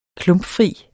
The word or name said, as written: klumpfri